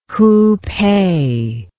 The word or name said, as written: Hupeh